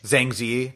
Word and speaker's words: Zhang Ziyi: Is it Zhang Zhang? How do you pronounce her name? – Zhang Ziyi.